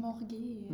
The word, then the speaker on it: Morgue: Also das Gedicht stammt aus einer Gedichtesammlung Morgu. Hoffentlich habe ich das jetzt richtig ausgesprochen. Das heißt Leichenschauhaus. – Du meinst Morg. – Morg. – Auf Englisch ist es Morg.